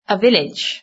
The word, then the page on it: a village: British English